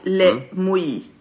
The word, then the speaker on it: Mouilles: Om man vill ha liksom två vingårdar som är lite bättre, så har vi då Le Capitan och Le Mouy. Lite svårt att uttalas, men stavas M-O-U-I-L-L-E-S. Just Le Mouy.